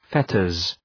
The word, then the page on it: fetters: ‘fetərz